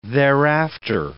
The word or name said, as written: thereafter